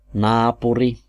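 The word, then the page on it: naapuri: IPA: [ˈnɑːpuri]; Finnish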